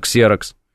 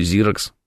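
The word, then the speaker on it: Xerox: Ну, написано «ксерокс». Почему «зирокс»? Почему? Ну, то есть, какое объяснение у вас? Потому что по-английски так произносится, пишет Квадрат Малевич.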